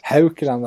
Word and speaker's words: Haukeland: Henrik Haukeland, va? Mm. Är det rätt uttal? – Ja, det låter bra. Det var länge sedan jag bodde i Norge. Men det känns tryggt. – Haukeland, ja.